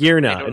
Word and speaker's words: Magearna: It's Magearna.